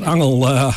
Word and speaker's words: Angel: Een angel van ook een wesp heeft dat angel. Dat heb jij kennis meegemaakt. Maar het angel in dit geval wordt op zijn Engels uitgesproken als Angel.